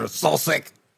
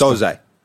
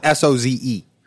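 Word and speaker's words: Söze: He's going like this: Kaiser Sosik, Kaiser Sosik. – I mean, how do you think it's? How do you I think say Soze? – No. – Yeah. Okay, it's spelled Soze. Soze.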